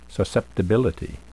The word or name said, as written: susceptibility